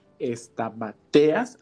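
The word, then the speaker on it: Stamateas: que es un escritor que se llama Bernardo Estamateas, así se pronuncia.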